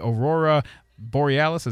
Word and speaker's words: aurora borealis: NOAA says that those in the northern parts of the country will have the best chance at seeing the aurora borealis.